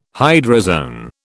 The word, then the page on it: hydrozoan: British English